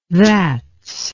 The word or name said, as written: thats